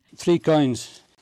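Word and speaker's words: Coynes: Three Coins. And it's spelled like three, then it's C-O-Y-N-E-S.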